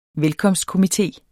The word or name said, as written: velkomstkomité